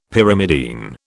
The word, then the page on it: Pyrimidine: British English